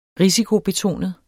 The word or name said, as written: risikobetonet